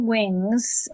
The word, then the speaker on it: Winge: Well, if they go to my film website, it's On Wings, mm-hmm. and it's based on my maiden name. So there's an E in there, so I'll spell it. My maiden name was Wing, W-I-N-G-E.